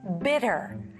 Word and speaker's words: bitter: Alright, so the first word is bitter. 자, bitter. 자, TT, 중간 TT는 특히 미국식 영어는 그냥 D, flap라고 D 발음이 거의 나죠.